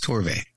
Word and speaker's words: Torve: I think that's how you say his name. Yeah, T- I believe it's Torve.